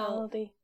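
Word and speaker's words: laldy: I guess it is like Aldi, but with an L in front. So Laldi.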